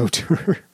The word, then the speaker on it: auteur: That was, yeah, that's how you pronounce auteur. Auteur, auteur.